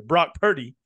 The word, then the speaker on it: Brock Purdy: And then, you know, you got some other players that kind of just blew up out of nowhere, a la block. Excuse my pronunciation here. Brock Purdy. Brock Purdy.